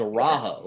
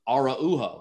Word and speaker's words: Araujo: Cause I thought it was Araujo. Like it's spelled like Araujo, which is like how all those names are said, but his was Araujo. And I was like, okay. And then and he had to like, and then he texted me like the phonetics of how to say it.